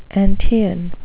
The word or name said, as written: antaean